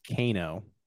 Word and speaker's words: Vizcaino: I think, did I get his name right? – I think um, it's Vizcaino. Oh, Vizcaino. Yes. Vizcaino. Yes. Yeah.